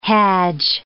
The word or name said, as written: hajj